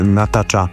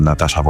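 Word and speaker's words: Natacha: To oczywiście Natasha Atlas, chociaż tak spolszczenie mówimy Natasha Atlas, ale pisze się to przez CH, a nie przez S.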